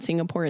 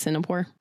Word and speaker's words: Singapore: I'm going to Singapore. – There you go. – Nope. It's actually. Did you know it's not Singapore? It's Singapore.